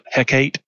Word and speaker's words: Hecate: We are probably going to a system called, I'm going to pronounce it, Hecate, H-E-C-A-T-E.